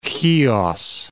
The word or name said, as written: Khios